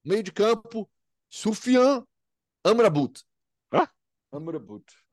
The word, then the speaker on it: Amrabat: A pronúncia mais próxima ali do, do que eles falam, e eu também tô, consigo falar o certinho, é algo assim: sufian Amrabat. Amrabat.